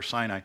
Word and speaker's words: Sinai: It's just short for Sinai. Pronounced different than Sin, but I won't bore you with that detail.